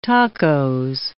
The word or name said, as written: tacos